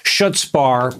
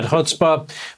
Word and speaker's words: chutzpah: Eugene Robinson, we have a new definition tonight of the word Richard Nixon is famously rumored to have pronounced schutzbar during his presidency. Most of us call it chutzpah, but its new definition is Senator Wicker from Mississippi.